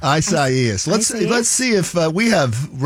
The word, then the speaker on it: Isaias: Please tell us how to pronounce it. – Really, I mean it's Isaias. – Isaias. – Isaias.